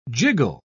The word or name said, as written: jiggle